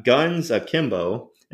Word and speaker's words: Guns Akimbo: And then next one, we got Guns Akimbo.